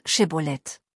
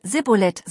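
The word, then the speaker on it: Schibboleth: Wenn er nein sagte, sechster, dann sagten sie, sprich das Wort Shibboleth. Wenn er Sibboleth sagte, konnte er es nicht richtig aussprechen.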